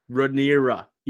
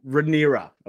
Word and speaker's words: Rhaenyra: meaning Rhaenyra. Is that what we keep saying? – Rhaenyra? – What is it? – Ray. – No, I got to get this right. – Rhaenyra. – Yeah, Rhaenyra.